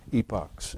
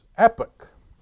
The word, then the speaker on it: epoch: One of the pronunciations offered, the first one was epoch. E-P-U-H-K. Epoch. And then it said, but, secondly, especially British. E-E-P-O-K.